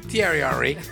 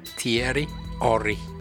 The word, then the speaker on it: Thierry Henry: Thierry Henry. I'll say that again. – Okay, say it again. – Thierry Henry. – Wow. – That's how you say it in France.